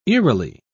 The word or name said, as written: eerily